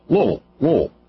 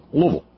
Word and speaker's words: Louisville: See, I said it right. Louisville. You know, it's almost like you're just kind of grunt. Louisville.